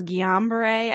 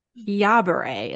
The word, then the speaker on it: Guillain-Barre: And I always thought it was Guillain-Barre, and I just looked it up and I'm like, I've been saying it wrong all these years. Guillain-Barre is what Mayo Clinic says.